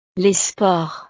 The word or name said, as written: les sports